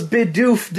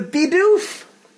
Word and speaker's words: Bidoof: Oh, we got Bidoof Day! July 1st was Bidoof Day. Or Bidoof, depends on your pronunciation. – What the frack is that? – It's a Bidoof!